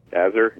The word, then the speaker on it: Azure: Azure, yeah.